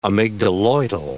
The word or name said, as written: amygdaloidal